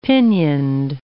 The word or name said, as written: pinioned